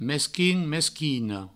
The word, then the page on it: mesquin: mesquina; mesquino